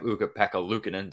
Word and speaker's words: Ukko-Pekka Luukkonen: You have Ukko-Pekka Luukkonen. Did I pronounce that right? I'm sure I did or didn't. – You absolutely nailed it.